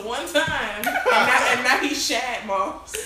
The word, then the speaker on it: Shad: It's Shad. Get put some Shad. Why do you give him a short A? That's not his name.